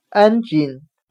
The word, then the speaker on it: engine: 我们可以用谐音法来记忆这个单词 engine，它的发音很像汉语的安紧，安装的安，松紧的紧。